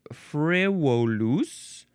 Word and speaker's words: frivolus: It's a Latin word, frivolus. That would be a V, which they pronounce as a W.